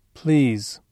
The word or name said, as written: please